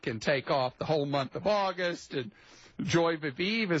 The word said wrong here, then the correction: joie de vivre: Is that how you say it in French? How do you say that? How do you say that, Krista? – I think it's joie de vivre.